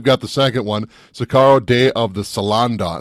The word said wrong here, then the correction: Soldado: Salando, I think is how you say it? – Soldado. – Soldado. There you go.